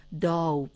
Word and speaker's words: داو: پس یک نکته‌ای که تا اینجا روشن می شود این است که کلمه داو با دور بازی ارتباطی ندارد، بلکه یک کلمه مستقل است و تلفظ درست آن هم داو بوده است.